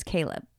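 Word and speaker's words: Chleb: L-E-B? – Yeah. – Or C-H-A-L-E-B? – No, it's C-H-L-E-B. It's spelled Schleb, but it's Caleb.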